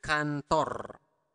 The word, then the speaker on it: kantor: Ke kantor. Ke kantor. Pronounce the R clearly.